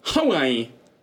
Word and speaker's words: Huawei: Heißt ja nicht Huawei, es heißt Huawei.